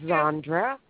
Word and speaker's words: Zandra: My name is Zandra. – Okay. Is it? Can you spell that for us? – Z-A-N-D-R-A.